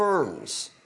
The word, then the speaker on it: Worms: He was commanded to appear at a church council that is known as the Diet of Worms. It's spelled W-O-R-M-S, which sounds like the Diet of Worms, which to me sounds like the title of a heavy metal album.